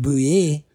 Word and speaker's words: Bouye: Come to find out, it's actually pronounced Bouye, and it's French. It's French-Canadian.